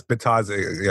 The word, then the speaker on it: Bitadze: I think it's Bitadze. Yeah, Goga Bitadze. I think it's how it's pronounced.